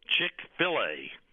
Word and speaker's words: Chick-fil-A: If you want the best chicken, Chick-fil-A. Chick-fil-A.